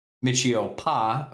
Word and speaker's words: Michio Pa: Michio Pa, who is a new character. And I said that right. The first name Michio, last name Pa.